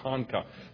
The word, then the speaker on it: Ponca: Am I saying that right? I used to call it Pocahontas, okay, but I now understand it's just Ponca.